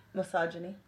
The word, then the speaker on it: misogyny: I can't even pronounce that. What does that say? misogyny. Oh, misogyny. There you go.